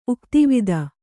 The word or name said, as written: ಉಕ್ತಿವಿದ